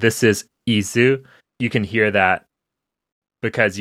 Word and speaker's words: qisu: Now, Isu is, I'm sure I'm not pronouncing it right, but it's a non-vocalized Q. It's spelled Q-I-S-U. You don't pronounce that Q, you make it a glottal stop instead.